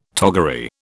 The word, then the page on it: toggery: British English